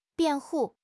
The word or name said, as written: 辩护